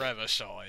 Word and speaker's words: Revachol: I may be pronouncing that name wrong. I know the loading screen says its pronunciation. – Yeah. it's Revachol, I think. – Revachol, yeah.